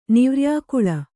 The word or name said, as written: ನಿವ್ರ್ಯಾಕುಳೆ